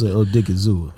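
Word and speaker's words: Osa Odighizuwa: Osa Ziggy Owen. Let me go and get that name out there. See, I know how to pronounce Osa Odigizua. – There you go.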